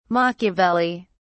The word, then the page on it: Machiavelli: British English